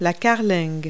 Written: keelson

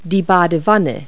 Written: bathtub

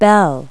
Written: bell

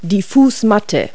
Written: floormat

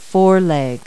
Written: foreleg

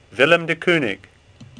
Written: Kooning

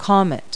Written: comet

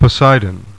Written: Poseidon

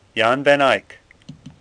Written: Eyck

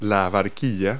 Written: gondola

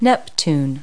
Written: neptune

